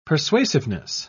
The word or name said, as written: persuasiveness